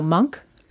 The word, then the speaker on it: Munch: Uh, the Scream by Edvard Munch. No, Monk. I don't remember how to say his name.